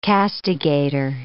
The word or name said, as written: castigator